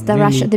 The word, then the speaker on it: pelmeni: And pelmeni, is that how you say it? – Oh, yes, pelmeni.